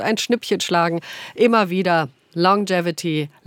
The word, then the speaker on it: Longevity: Wir reden über Longevity. So wird das ausgesprochen. Longevity, Langlebigkeit. Stimmt doch, oder? Longevity, habe ich richtig ausgesprochen? – Korrekt.